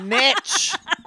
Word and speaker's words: niche: The word N-I-C-H-E can be pronounced both ways. – Go fuck yourself. – Niche.